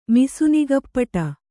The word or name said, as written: ಮಿಸುನಿಗಪ್ಪಟ